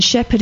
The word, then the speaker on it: Shepherd: I'll spell my surname because it's D Shepherd Massage Therapy, and Shepherd is spelled S H